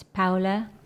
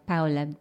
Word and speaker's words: Paula: I'm known as Paula here, but it's Paula. – Okay. – My nickname is Pau, so Paula.